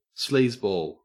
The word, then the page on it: sleazeball: Australian English